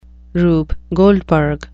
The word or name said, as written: rube goldberg